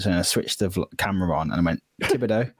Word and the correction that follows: Tibidabo: Red light syndrome. – Oh mate, it's so bad. Like I was listening to pronunciation. I was like, yeah, right, Tibidabo.